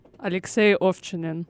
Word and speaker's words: Alexei Ovchinin: Alexei Ovchinin.